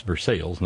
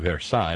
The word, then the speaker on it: Versailles: Yes, that's Versailles, not Versailles like in France. It's Versailles, or as my father used to pronounce it, Versailles. Kind of like Versailles. No, it's Versailles.